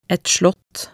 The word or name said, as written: et slott